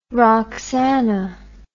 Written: roxana

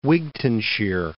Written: Wigtownshire

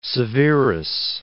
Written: Severus

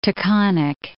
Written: Taconic Range

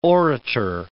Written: orator

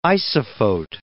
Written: isophote

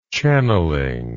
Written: channeling

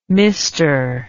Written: mr

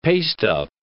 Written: pasteup

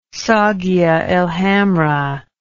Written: saguia el hamra

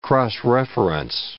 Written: cross-reference

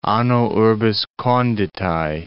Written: anno urbis conditae

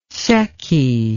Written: secchi

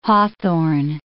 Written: hawthorn

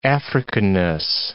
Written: Africanness

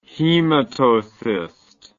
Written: hematocyst

